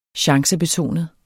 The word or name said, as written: chancebetonet